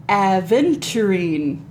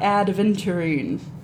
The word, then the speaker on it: aventurine: you can pair it with citrine, tiger's eye, and aventurine. Not adventurine.